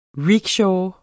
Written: RICKSHAW

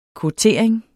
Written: kvotering

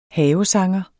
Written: havesanger

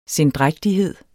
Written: sendrægtighed

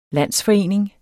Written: landsforening

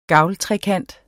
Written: gavltrekant

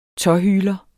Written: tåhyler

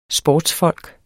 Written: sportsfolk

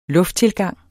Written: lufttilgang

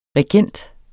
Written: regent

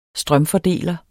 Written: strømfordeler

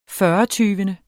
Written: fyrretyvende

